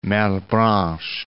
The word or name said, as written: Malebranche